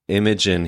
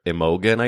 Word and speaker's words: Imogen: So I think you actually pronounce her name Imogen Heap. – Okay. – And he, on one of the clips of their podcast, Carrie calls her Imogen, I think. And it was a gorgeous mispronunciation.